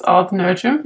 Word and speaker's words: Odd Nerdrum: Did I say his name correctly? – Mm-hmm. In English, it's Odd Nerdrum, yeah. – In English. Okay, fair enough. – It's strange to call somebody odd in English. – Oh, but his actual name would be Odd? – You would pronounce it a bit different in Norwegian, yes.